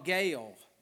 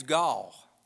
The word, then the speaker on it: Gaal: Now, his name is spelt G-A-A-L. But Alexander Scorby said Gaal. And so that's my authority on how to pronounce his name. If I ever don't know how to pronounce anybody's name, I go and listen to how Alexander Scorby said it. That's a pretty good reference point. He called him Gaal. And I looked and looked. How would you pronounce that? But it's pronounced Gaal. But his attitude's gall.